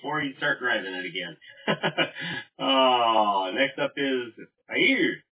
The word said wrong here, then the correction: Yair: I probably still haven't pronounced that right. W6YMJ. – Hey, good evening. This is Yair, W6YMJ. I'll take Alpha Bravo Charlie. Thanks. Have a good night. – Yeah, Yair, it's right there. It's right there in front of me. I just keep not, I'm looking at it and I keep not pronouncing it correctly. Yair.